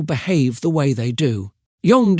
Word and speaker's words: Jung: Carl Jung, pronounced Jung, was a wise thinker who lived about 100 years ago and spent his life studying the human mind.